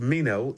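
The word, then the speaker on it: meno (μένω): Now, the Greek word abide here is pronounced meno, M-E-N-O, and it means to remain where it is, to continue in a fixed state, to endure. Meno, meno, stay right where you are.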